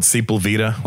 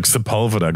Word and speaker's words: Sepulveda: I used to call it Sepulveda when I first moved in there. I didn't know how to say it. It's like Sepulveda.